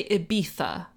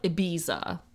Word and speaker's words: Ibiza: I believe, if I'm not mistaken, in Catalan, and in Spanish, like Castilian Spain, or Spanish, the sound is pronounced as a th. Like, apparently it's actually Ibiza, not Ibiza, like people sing about.